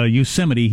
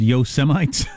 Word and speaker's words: Yosemite: Yosemite, he pronounced Yosemites.